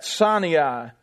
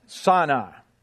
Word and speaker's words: Sinai: In verse 8, uses poetic imagery to describe the revelation of God's holiness at Sinai. I think I say that wrong. I think I say that the redneck way out of habit. Sinai, I think is the right way to say that.